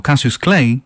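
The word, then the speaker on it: Cautious Clay: Suena como Cassius Clay, aunque se escribe cautious.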